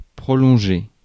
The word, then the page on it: prolonger: France; [pʁɔ.lɔ̃.ʒe]